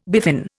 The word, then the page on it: biffin: Indian